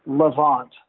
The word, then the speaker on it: Levant: Levant.